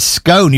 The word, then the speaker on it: scone: I mean, obviously, it's scone. You pronounce it exactly the way as you pronounce gone.